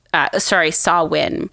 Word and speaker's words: Samhain: It's pronounced Samhain. It's Samhain. It totally sounds differently than it's spelled,